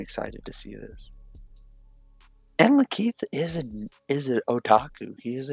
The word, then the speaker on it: Lakeith: Lateef, if I'm pronouncing his, Lakeith Stanfield. Lakeith. I loved him, and Sorry to Bother You, it's probably one of my favorite movies.